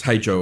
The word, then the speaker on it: Hej: The guy's name is Paul Vater, or Wather, W-A-T-H-E-R. We'll say Vater. Name of the track, Hej Joe, or H-E-J Joe, or pronounced Hey Joe, of course.